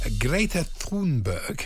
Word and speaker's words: Greta Thunberg: Greta Thunberg.